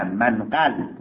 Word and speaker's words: منقل: و هم گفت منگل. آقا منگل نه، منقل.